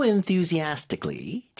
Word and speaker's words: enthusiastically: She's been reading it so enthusiastically. 자, 이게 이제 열심히에 해당하는 단어입니다. 발음 좀 도와드릴게요. 워낙 음절이 많으니까. – Enthusiastically. – Enthusiastically. – Yeah. – Astically. 거기 강세 잘 주세요.